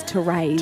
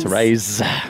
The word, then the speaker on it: Therese: I believe the pronunciation is Therese. – Therese.